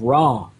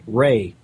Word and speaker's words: Re: Well, Apophis, that name, Apophis itself, it's the Greek name of the ancient Egyptian enemy of Ra, or more correctly pronounced, Re.